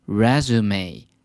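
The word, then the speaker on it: résumé: 是要念成 resume，它是源自于法文，指的就是履历表。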